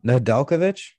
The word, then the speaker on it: Nedeljkovic: N- N- Nedeljkovic.